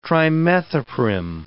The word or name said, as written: trimethoprim